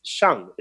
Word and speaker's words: shang: And the sound for the lungs, the sound for the metal element is shang. It's spelled S H a N G.